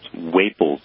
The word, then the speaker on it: Wapples: The last name is pronounced Wapples, right? – Wapples, got it. Robert Wapples, like Staples Superstore.